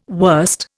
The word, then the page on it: worst: Indian